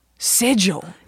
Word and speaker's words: Sigil: Sigil. – Yeah, it like almost sounds like there's a D in there. But there's not. – Because it's S-I-G-I-L.